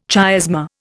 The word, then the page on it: chiasma: Indian